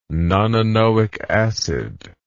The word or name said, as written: nonanoic acid